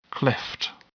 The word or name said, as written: cleft